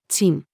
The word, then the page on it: チン: female